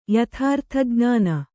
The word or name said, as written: ಯಥಾರ್ಥಜ್ಞಾನ